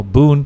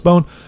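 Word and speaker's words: Boon: which Americans call Boone, whatever it's pronounced Bone.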